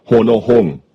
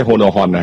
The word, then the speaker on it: โฮโนฮง: อ่านว่าโอ้ต้องเสียงว่าโฮโนฮงโฮโนฮงนะไม่ใช่โฮโนฮอนนะฮะ